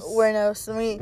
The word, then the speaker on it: Wuornos: Wuornos? – Wuornos.